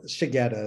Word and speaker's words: Shigeta: Well, Shigeta is how I pronounce it.